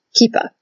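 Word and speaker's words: kippah: And also, a big thank you to Sarah, who I actually happen to know in person, uh, who corrected my pronunciation. I hope I'm pronouncing it better this time. Kipa and kipot for the plural.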